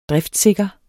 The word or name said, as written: driftssikker